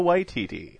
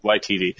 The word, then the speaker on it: Waititi: Waititi is the last name? – Taika Waititi. – Waititi.